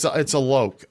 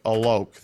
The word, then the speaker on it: Alok: It's Alok. Alok. That's how it's pronounced.